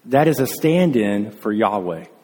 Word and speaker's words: Yahweh: Now, I am in Hebrew is pronounced Yahweh.